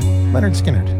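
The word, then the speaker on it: Lynyrd Skynyrd: Leonard Skinnerd, pronounced Leonard Skinnerd.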